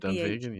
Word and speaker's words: Dunvegan: Okay, so it's Ian McLeod, independently bottled, Rosebank 91, Dunvegan, I think is how you pronounce it. – Dunvegan, yeah.